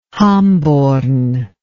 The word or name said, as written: hamborn